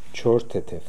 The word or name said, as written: Choortetev